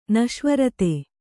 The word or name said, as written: ನಿಃಸ್ವಾರ್ಥ